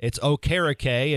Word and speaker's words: Okereke: and so now it's Okereke, and so that's what we're going to go with from here on out to honor Bobby Okereke and, quite frankly, get his last name pronounced correctly the way he wants it to be.